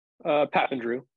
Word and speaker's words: Papandrew: Am I pronouncing that right? – Papandrew, pretty close.